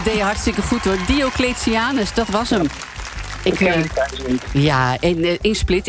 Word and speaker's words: Diocletianus: Het paleis van Cletianus. Het paleis van Nou, nog een keer. Diocletianus in Split, Kroatië.